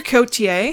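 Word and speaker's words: Côté: Mr. Cotier had informed, sorry, I'm debating how to say his name because it's C-O-T-E with a thing.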